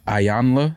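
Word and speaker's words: Iyanla: But after knowing the history of the relationship with DMX and his kids, when they were on Iyana, how do you say her name? Iyana Van Zandt. – Ayanla.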